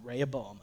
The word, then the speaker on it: Rehoboam: This is why I wanted the paper, just to show you, like, tell me if there's a better way to pronounce this, people. I'm telling you, Rehoboam.